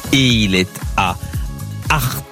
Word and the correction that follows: Artix: Artix. On prononce bien le X de Artix, comme Astérix.